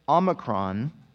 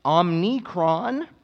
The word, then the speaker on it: Omicron: Though ironically, I am one of a handful of people in America who can correctly pronounce the word Omicron or Omicron. It is not pronounced Omnicron, Omnicorn, or Optimus Prime. Those are all incorrect pronunciations.